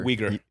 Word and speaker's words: Uyghur: Is it Uyghur or Uyghur? – Uyghur. – Uyghur? – Yeah. – Okay. Uyghur I've been saying it wrong this whole time.